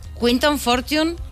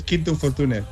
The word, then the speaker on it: Quinton Fortune: Quinton Fortune, ¿lo hemos pronunciado bien? – Sí, sí. Bueno, aunque normalmente en España es Quinton Fortune, pero es igual.